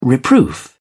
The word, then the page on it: reproof: British English